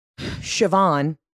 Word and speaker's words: Siobhan: And if I have a girl, D'Angela Siobhan, but it's spelled S-I-O-B-H-A-N. It's a Celtic spelling.